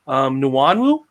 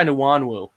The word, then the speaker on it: Nwangwu: I'm going to try Nwanwu. Soft G there. Nwanwu. Trying it. – It's probably maybe. Smith or something. – Yeah, it probably is. It's probably Nwangwu. It's like the hardest G ever.